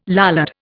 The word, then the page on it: Lollard: Indian